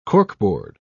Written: corkboard

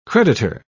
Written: creditor

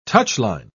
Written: touchline

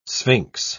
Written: sphinx

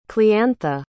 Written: cliantha

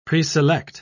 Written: preselect

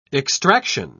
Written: extraction

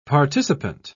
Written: participant

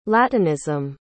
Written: latinism

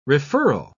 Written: referral

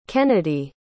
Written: kennedi

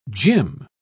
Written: gym

